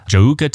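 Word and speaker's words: Djouka: It's D-J-O-U-K-A, and I'm pretty sure the D is silent. I'm pretty sure it's Jouka. Jouka Tesla.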